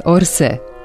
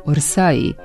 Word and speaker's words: Orsay: Na francuskom ime ovog muzeja piše se Orsay sa grupom AY na kraju. Ukoliko poslednje ne sledi izgovorni samoglasnik, izgovor grupe AY u francuskom najbliži je izgovoru našeg E. Suglasnik J ne čuje se u francuskom izgovoru ove grupe glasova, pa se kod nas ni ne prenosi.